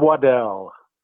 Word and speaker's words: Waddell: Rube Waddell.